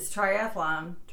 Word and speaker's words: triathlon: But most people add an extra A. – Yeah, I might do that. – What, triathlon? – Mm-hmm. – It's triathlon. It says people everywhere, triathletes included, love to pronounce it as triathlon.